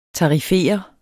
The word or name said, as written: tarifere